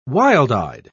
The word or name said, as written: wild-eyed